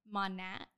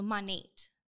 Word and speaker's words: Monat: So it's actually, I pronounce it wrong. I like the name Monet, but it's Monat, because it's modern nature. So Monate, modern nature.